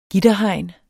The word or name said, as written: gitterhegn